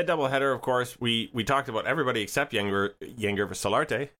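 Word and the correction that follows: Yangervis: Yen Hervis. – Okay, why is the G pronounced like an H?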